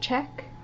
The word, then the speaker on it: Czech: Is it Czech? Am I saying that right now? – Yeah.